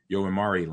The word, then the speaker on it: Yomiuri: Yomiuri Land.